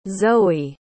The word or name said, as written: zoie